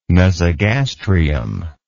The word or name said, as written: mesogastrium